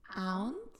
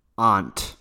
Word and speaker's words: aunt: Aunt. – Pues tienes que pronunciarlo así como aunt. – Aunt. – Y también las personas a veces dicen aunt. – Aunt. – Sí, depende de la persona.